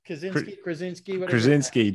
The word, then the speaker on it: Krasinski: Krasinski, Jim from the Office.